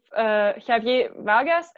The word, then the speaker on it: Javier Vargas: And so have you been touring with Javier Vargas? Am I saying his name right? Because I'm never sure about Spanish pronunciation. – yes, that's good. Yeah. Good pronunciation.